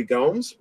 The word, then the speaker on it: Gomes: And we've actually had some of our Brancy Slackers, you know, win some money, namely Henner YYZ, aka Henry Gomes, rhymes with Holmes, and Lil Stevie!